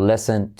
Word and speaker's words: listen: Por cierto, listen, letra clave L, O-O-O. Letra clave I como E. No sería listen, sino listen. Y la T no se pronuncia.